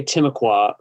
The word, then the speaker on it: Timucua: Yeah, so we say Timucua, but of course they're totally extinct. So it's hard to say how it's actually pronounced. I've never found someone to correct me on saying Timucua, but that's how we say it here.